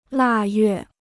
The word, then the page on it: 腊月: là yuè